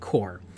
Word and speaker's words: Corps: And he's like, no, when you have C-O-R-P-S, it's pronounced core.